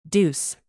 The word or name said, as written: deuce